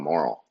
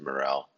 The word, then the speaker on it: morel: You know, the other day I was talking to my dad about the morel mushrooms, and you know, when I first seen the word morel, I thought it was called a moral. I guess it's how you pronounce it, but it's typically pronounced morel.